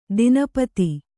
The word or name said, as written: ದಿನಪತಿ